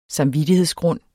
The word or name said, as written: samvittighedsgrund